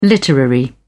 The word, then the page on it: literary: British English